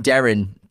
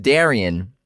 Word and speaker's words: Derynne: We keep saying Darren, but it's pronounced Darian.